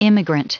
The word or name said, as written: immigrant